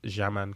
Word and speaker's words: Xaman: And we stayed at this place called Jaman Condos, spelt with an X-A-M-A-N.